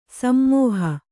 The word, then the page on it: ಸಮೂಹ: Kannada